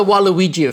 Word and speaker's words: Waluigi: I haven't heard of it, but you would like to introduce the Waluigi effect. Is that how I pronounce it, or how do I need to pronounce it? Tell me. – You're clearly not a gamer, Stephen. No. So it's the Waluigi effect.